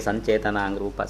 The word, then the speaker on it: สัญเจตนา: แปลว่าสม่ำเสมอเนืองๆก็คือยังรู้อยู่เนืองๆก็คือยังไม่ลืมนั่นแหละสัญญายังไม่รู้ยังไม่ลืมรูปเปสัญญารูปสัญญาความจำรูปได้ความรู้รูปรู้ในรูปนะรูปสัญเจตนาก็เหมือนกันก็รูปเปสัญเจตนารูปสัญเจตนาสัญเจตนามันมีจุดอยู่ข้างล่างต่อเต่าเอาออกด้วยนะเดี๋ยวจะอ่านว่าสัญเจตนา